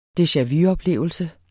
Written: deja-vu-oplevelse

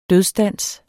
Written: dødsdans

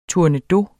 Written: tournedos

